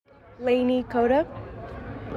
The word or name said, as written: Laini Cota